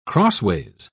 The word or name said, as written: crossways